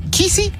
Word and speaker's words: Kesey: Kesey, I guess is how you say his last name.